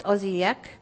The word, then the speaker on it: Osijek: So we started in Budapest, and we went to a place called, it's pronounced Osijek, and that is actually in Croatia. This is not a great map because the big writing here is going right across Croatia. But Osijek is right near the border of Serbia and Croatia.